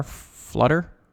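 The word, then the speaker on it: Phluttr: It's P-H-L-U-T-T-R, Flutter.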